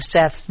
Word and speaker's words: Seth: As in the case of naming Cain, Eve explains theologically the choice of Seth's name. The Hebrew name Seth is related by sound and is pronounced Set, if not originally, to the Hebrew word for granted, pronounced Sat.